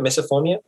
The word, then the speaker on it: misophonia: I know I have it because when I read the description of misophonia, am I pronouncing it right? Misophonia? – Misophonia.